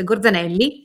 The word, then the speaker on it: Gorzanelli: Sì, fai di live su argomenti di missioni spaziali e poi farò un live sul, sulla radioattività dintorni con eh, Francesca Gorzanello. Sì, aspettate che l'ho detto bene. Francesca Gorzanelli, chiedo scusa.